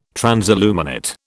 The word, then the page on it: transilluminate: British English